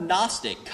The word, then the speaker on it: Gnostic: And that word Gnostic comes from the Greek word for to know, and has a silent G, so it's gnostic, just like we have knowledge, right? That's why we have knowledge, silent K, is that because it comes from the word Gnostic.